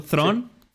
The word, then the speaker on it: Thrawn: No sé si pronunciarlo Thrawn, sí.